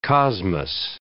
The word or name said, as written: cosmos